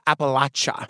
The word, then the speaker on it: Appalachia: Thank you for saying Appalachia. – I know, because you corrected me last time. Wait. Hear a bell for me. – They'll throw an Appalachia if you say it wrong.